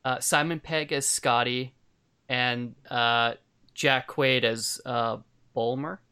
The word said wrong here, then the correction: Boimler: Is that how you say his name? – Oh, my God. Yeah. Boimler, I think.